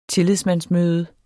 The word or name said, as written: tillidsmandsmøde